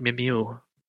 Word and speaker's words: Mimieux: And because of that, she couldn't film her role because she was too injured, and so she was then replaced with Yvette Mimieux. – Wow. – Again, I don't know if that's how you pronounce her last name. – No, that's pretty, that's good. Yeah, it's French.